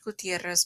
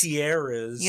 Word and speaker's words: Gutierrez: because you can't roll your it's R's. Gutierrez. You don't know how that's to roll your Mexican R's.